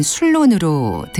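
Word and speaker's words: Ceylon: 그리고 노래 속에 지금의 스리랑카인 실론이 아일랜드식 발음인 술론으로 등장하는데요.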